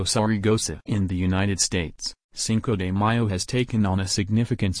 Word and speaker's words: Cinco de Mayo: Cinco de Mayo, pronounced Cinco de Mayo, Spanish for 5th of May, is a celebration held on May 5.